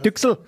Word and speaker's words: Duxelles: Ja, ist halt die fränkische Aussprache. Ich kann das auch nicht. Anders. Düchsel. genau, so ähnlich, ja.